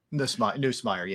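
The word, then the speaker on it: Nussmeier: Neusmeyer? – Neusmeyer, yeah.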